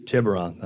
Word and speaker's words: Tiburon: Tib, Tibor, Tiburon. Tiburon. Thank you.